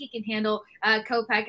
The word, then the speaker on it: Kopech: Kopech.